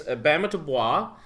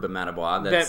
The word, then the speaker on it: Bamatabois: actually turning out to be the jack of all trades in the cast because he also plays Bamatabois, which is a role that I think is really cool, and I'd like to try it once.